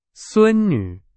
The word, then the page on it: 孙女: Chinese